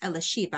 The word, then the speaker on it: Elisheba: It's pronounced Elisheba. I think that's right in Hebrew.